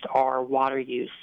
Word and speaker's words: Our H20use: So this latest house that I helped build, you know, it's called Our House, but it's spelled Our H20use, so Our House, or pronounced Our Water Use.